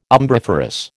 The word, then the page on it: umbriferous: American English